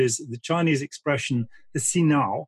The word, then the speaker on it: hsinao: H-S-I-N-A-O, Sinao, means the cleansing of the heart.